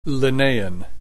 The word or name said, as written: Linnaean